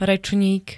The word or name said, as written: rečník